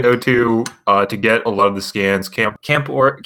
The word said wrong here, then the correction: camphor: Tree, however it's pronounced. I just know Camphor Tree. – Camphor. – Okay. It's camphor? – Yeah.